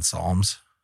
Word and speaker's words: psalm: I asked ChatGPT about this, and the word psalm is written with a silent P due to its etymology.